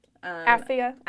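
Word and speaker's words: Apphia: So what, like in the beginning of the letter, it says to Philemon, our beloved fellow worker, and a name I can't pronounce. – Aphia and Archippus. – Yep, beautiful.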